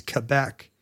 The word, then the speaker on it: Quebec: For a long time there was emphasis on saying Quebec because that would mean there would be one Ch instead of being K-K-K, it would be Ch-K-Qua. But in fact, that did not stick around. The official pronunciation is Quebec.